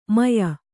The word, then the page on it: ಮೆಯಿ: meyi